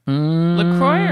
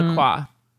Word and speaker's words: LaCroix: LaCroix or LaCroix? – I read on their website that you can pronounce it either way.